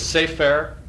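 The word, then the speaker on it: Sefer (ספר): It starts here, up in this upper right-hand corner, and it says Sefer. That's an S and a soft P. And an R, Sefer Torah, okay?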